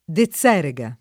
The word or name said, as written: Dezerega